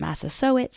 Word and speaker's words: Massasoit: And I heard a Mashpee Wampanoag person named Carrie Helm pronounce it Massasoit, so I say it that way on the podcast, on this series. I also read that his name was actually pronounced Massasoit.